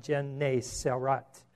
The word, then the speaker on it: Gennesaret: Like I said, the place is the lake of, and it's pronounced Gennesaret, I believe is how it's pronounced.